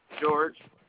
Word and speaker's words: George: George. – George. That's G-E-O-R-G-E.